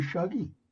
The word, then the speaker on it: Shuggie: Because in high school, Rob's nickname was actually Shuggie. – And that was because it rhymes with thuggy.